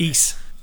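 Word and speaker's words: Ys: It is Ease, isn't it? Ease, like geese.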